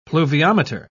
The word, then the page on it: pluviometer: British English